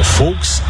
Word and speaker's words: Volks: As qualidades do Fusca, uma adaptação popular de Volks, que se pronuncia em alemão Volks, começaram a se espalhar pelo país.